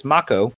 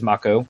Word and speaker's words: Mako: I'm sorry, it's actually, now I know it's Mako. Like Legend of Korra is Mako. That's how it's pronounced.